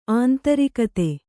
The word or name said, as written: ಅಂತರ್ಗತ